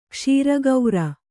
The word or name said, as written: ಕ್ಷೀರಗೌರ